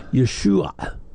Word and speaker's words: Yeshua: It's an ah sound. We it doesn't exist for us. – Yeah. – So it, the proper pronunciation would be Yeshua. But again, most Americans can't make that last sound. They can do the SH, not the ayin.